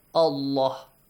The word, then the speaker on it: Allah: So the rule says, if you have a fatha or a kas or a dhamma right before the word Allah, pronounce the word Allah as Allah. وَأَطِيعُ اللَّهَ. Lam is pronounced with a full mouth and heavy sound.